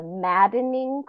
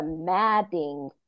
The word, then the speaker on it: Madding: But I did also watch Far From the Madden. See, I said it wrong. I always thought for years it was Far From the Maddening Crowd, but it is Far From the Madding Crowd.